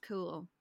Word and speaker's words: Cumhaill: it's spelled Mac and then C U M H A I L L, and somehow that's pronounced cool.